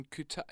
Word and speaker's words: Qatar: Don't think of it as a Q. Yes. It's not, you know, like, it's not Qatar.